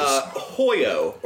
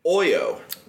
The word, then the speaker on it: Hoyo: Hoyo, or as the Spanish call it, Oyo. Because you don't pronounce the H.